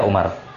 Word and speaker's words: Amr: Nah, maka kata para ulama, kalau dia Amr maka ditambahkan waw supaya orang tahu bahwa bacanya ini Amr. Karena kalau tidak ada waw maka dia dibaca apa? Berarti dia ini dibaca Umar. Dibaca Umar.